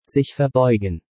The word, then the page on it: Sich verbeugen: German